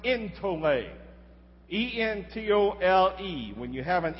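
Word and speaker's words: entole (ἐντολή): It goes like that, and it's an eta. And it's pronounced as an A. That's why we pronounce it entole.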